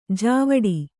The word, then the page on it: ಜಾವಡಿ: jāvaḍi